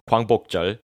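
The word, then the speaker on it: Gwangbokjeol (광복절): In South Korea, it is known as Gwangbokjeol, my Korean pronunciation, Gwangbokjeol, literally the day the light returned, and is one of the public holidays in South Korea.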